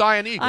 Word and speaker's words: Ian Eagle: No, it's Ian Eagle. I've known Ian Eagle forever.